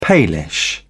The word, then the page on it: palish: British English